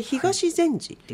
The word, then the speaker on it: 東善寺: これ東善寺っていうんですか、はい、読むんですか。 – 東善寺と読みます。